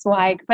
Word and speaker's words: Zweig: It's Zweig, but it's okay. Right. Everyone gets my name wrong. It's a thing. And I embrace it. It's a hard name. It's a hard name. So and I'm Zweig, but you were close.